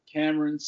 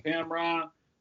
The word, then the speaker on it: Camron: You can find me everywhere on social media at Camron Smith. No E in Camron. Again, as I always like to say, Camron, a rapper, made my name cool. When I pronounce it Camron, I got more love for you.